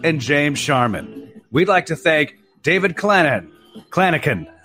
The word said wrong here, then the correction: Clanachan: It's Scottish. Klinakin, like a hard C.